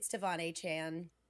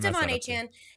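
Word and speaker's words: De'Von Achane: It's Devon Achane. I said it right. Yes. – You did. Me. I know. – It's Devon Achane.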